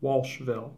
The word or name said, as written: Walshville